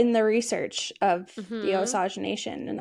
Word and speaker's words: Osage: Am I saying this right? Osage? – The Osage? Mm-hmm.